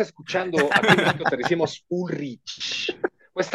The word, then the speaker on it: Ulrich: Y pues eran bien fans, sobre todo Lars Ulrich de Dinamarca, que es la, la forma correcta de decir su apellido, porque un, chorro de veces nos han dicho, así no se dice Ulrich, no se dice, bueno, es Ulrich, ok, así.